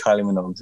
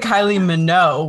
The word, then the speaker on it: Minogue: I think I just said Kylie Minogue without like the G. I think I played with the silent G.